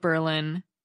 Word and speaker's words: Berlin: The name of the town that looks like Berlin, Connecticut is actually pronounced Berlin. Mark and Ed both wrote in, apparently it's a quick way for locals to identify people from out of state, a shibboleth, if you will. Mark said that, quote, during World War II, the town council passed a law declaring the pronunciation of the town's name to have an emphasis on the first syllable to distinguish it from the then enemy German city, unquote.